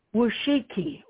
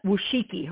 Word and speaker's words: Wojcicki: Gail went to the CEO of YouTube, And the woman's name is Susan Wojcicki. It's hard to pronounce. I think it's pronounced Wojcicki. Her last name is Wojcicki.